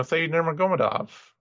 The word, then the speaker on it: Said Nurmagomedov: It's, you know, Said Nurmagomedov. – But treat, it, treat the Nur as like a prefix on yeah.